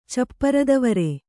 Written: ಚಪ್ಪರದವರೆ

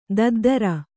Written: ದದ್ದರ

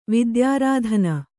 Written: ವಿದ್ಯಾರಾಧನೆ

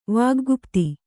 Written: ವಾಗ್ಗುಪ್ತಿ

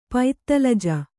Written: ಪೈತ್ತಲಜ

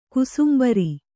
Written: ಕೋಸುಂಬರಿ

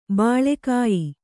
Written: ಬಲಗೆಯ್

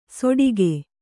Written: ಸೋಟಿಗೆ

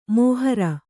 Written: ಮೋಹರ

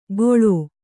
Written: ಗೊಳೋ

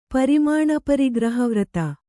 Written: ಪರಿಮಾಣಪರಿಗ್ರಹವ್ರತ

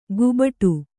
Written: ಗುಬಟು

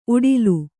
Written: ಊಟಲೆ

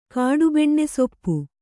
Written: ಕಾಡುಬೆಣ್ಣೆಸೊಪ್ಪು

